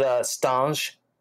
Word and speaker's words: Stange: And basically what it is, they've got these round trays that have holes where they put the, I know I get the name of this wrong, this glass wrong. Stang or Stange. Do you know the glasses I'm talking about? – Yeah, I've always pronounced it Stange. – Stange.